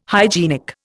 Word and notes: hygienic: Indian

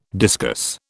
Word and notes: discuss: British English

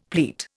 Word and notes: pleat: Indian

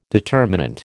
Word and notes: determinant: American English

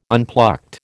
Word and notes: unplucked: American English

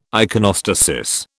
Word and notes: iconostasis: British English

